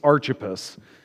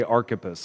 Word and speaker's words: Archippus: Now, first off, if I mess up this name, I apologize, because I've been saying it for the past 2 weeks, Archippus. And then my wife corrected me last night and said, "Are you sure that's how you say it?" My wife is great at correcting me on that. And we looked it up, and in fact, the majority of the pronunciations say Archippus.